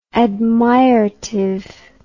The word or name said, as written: admirative